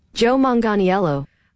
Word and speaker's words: Manganiello: I tried to, here's how we pronounce it. – Joe Manganiello. – Manganiello. I've never heard it said like that.